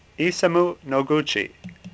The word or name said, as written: Noguchi